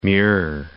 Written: Muir Woods National Monument

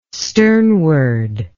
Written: sternward

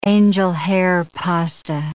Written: angel-hair pasta

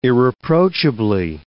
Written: irreproachably

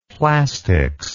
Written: plastics

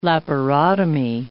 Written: laparotomy

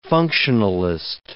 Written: functionalist

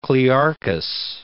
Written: Clearchus

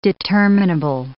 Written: determinable

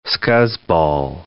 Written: scuzzball

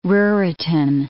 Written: Ruritan

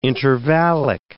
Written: intervallic

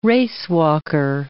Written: racewalker

